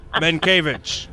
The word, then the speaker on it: Mankiewicz: Okay, can you say that name again? – Doug. – Now, D-O-U-G. – Now batting, Doug Mankiewicz. – Man. – I can't believe that's how you pronounce that name. I would have said it so differently.